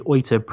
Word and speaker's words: Oita: It's actually held races for Super Formula, Super GT, and it's held in the, I think it's pronounced the Oita Prefecture.